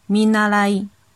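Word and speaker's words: 見習い: 米纳莱，米纳莱，米纳莱，写成汉字是“ 看见” 的“ 见”，然后是学习的“ 习” 的繁体字，再加一个平假名的“ 一”。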